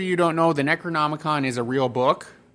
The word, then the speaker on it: Necronomicon: the Evil Dead's Necronomicon.